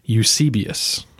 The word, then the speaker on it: Eusebius: David pronounces his name Eusebius, and I'm sure he knows better than I do.